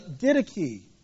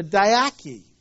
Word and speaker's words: Didache: Again, it'd be tested, and in fact, in the very end of the first century, they actually wrote a book called the Didache, or the Didache, depends how you pronounce it.